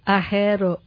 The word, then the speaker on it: Ahero: A-H-E-R-O. – Correct. – Like a hero. – That's right. The only thing Ahero means in Luo, the local language, I love. – Ahero.